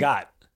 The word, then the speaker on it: Zagat: It's Zagat.